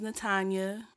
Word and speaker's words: Natanya: My name's Natanya. It rhymes with lasagna.